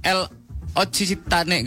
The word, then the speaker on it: L'Occitane: Nih, lo tau lo pernah baca brand L Occitane gak? – Iya. – Itu apa tuh baca itu? Bacanya L'Occitane. L'Occitane.